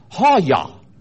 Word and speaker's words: haya: The word was basically comes from a Hebrew word pronounced haya, haya.